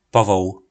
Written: povol

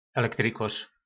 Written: elektrykor

